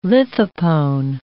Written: lithopone

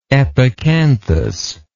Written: epicanthus

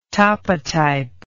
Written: topotype

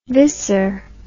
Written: viscer

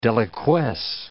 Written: deliquesce

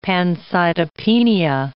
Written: pancytopenia

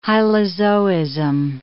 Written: hylozoism

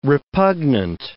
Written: repugnant

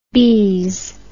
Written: bise

